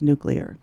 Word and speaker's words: nuclear: Well, this is really so simple and such a huge issue of contention, but the pronunciation is nuclear. – Nuclear. – Yes, it is nuclear.